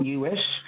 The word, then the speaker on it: us: Okay, it is in fact U-S, and it's us with an S sound, and it's been part of English for about 1,000 years.